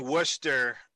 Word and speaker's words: Worcester: Well, when I lived in Worcester, and I even pronounced that right, we actually went up to Clinton to hear some folk music.